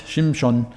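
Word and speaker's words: Shimshon (שמשון): Sie haben einen Sohn bekommen und sie hat ihm den Namen Simson, sagen wir auf Deutsch, Shimshon, ist eher die hebräische Aussprache.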